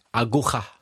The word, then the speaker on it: aguja: Aguja. Yeah. How do you say it? – Aguja. – Aguja. Yeah, a needle match would be, for example, two London teams playing against each other.